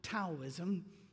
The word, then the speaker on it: Taoism: Taoism, which is spelled Taoism, but for some reason it's Taoism.